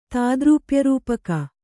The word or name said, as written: ತಾದ್ರೂಪ್ಯರೂಪಕ